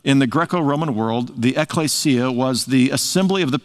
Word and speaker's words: ekklesia: In the New Testament, the Greek word that's translated church is ekklesia.